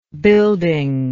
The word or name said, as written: buildingless